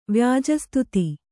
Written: ವ್ಯಾಜಸ್ತುತಿ

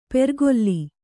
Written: ಪೆರ್ಗೊಲ್ಲಿ

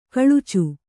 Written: ಕೊಳಚೆ

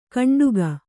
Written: ಕಂಡುಗ

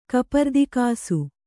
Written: ಕಪರ್ದಿಕಾಸು

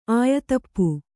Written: ಆಯತಪ್ಪು

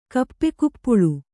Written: ಕಪ್ಪೆಕುಪ್ಪುಳು

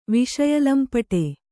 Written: ವಿಷಯಲಂಪಟ

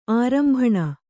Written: ಆರಂಭಣ

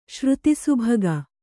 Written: ಶ್ರುತಿಸುಭಗ

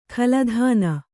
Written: ಖಲಧಾನ

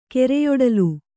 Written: ಕೆರೆಯೊಡಲು

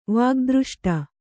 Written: ವಾಗ್ದøಷ್ಟ

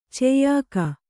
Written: ಚೆಯ್ಯಾಕ

